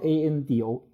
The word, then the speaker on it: Ando: 这就嗯，Ando 就是 A A A N D O，就是 Ando Ando 电器，就是完全日日语发音呃过来。